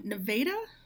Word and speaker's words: Nevada: Jeremy works at a counter in the video hut in Nevada, Iowa. It is a small town. The first A in the name is pronounced as A, smack in the center of the state.